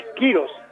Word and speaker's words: Quiroz: Para mí es Quiroz, porque termina en Z. Y los Quiroz que terminan en Z tienen un acento en la I, ¿no?